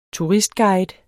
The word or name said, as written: turistguide